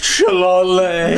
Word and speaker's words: Shillelagh: So it's pronounced Shillelagh.